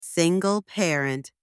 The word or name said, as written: single parent